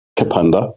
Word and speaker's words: Kapunda: I'm going to pull you up on that one. It's very close. It's Kapunda. That's actually close to the pronunciation of its original name. It's Kapunda. – Kapunda. – You have to be a little bit Australian and put just the K, and then there's no A in there, so it's just Kapunda.